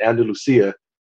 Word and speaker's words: Andalusia: I don't want to Andalusia.